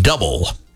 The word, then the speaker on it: Double: The axe name is spelled like the word double. It is pronounced Double.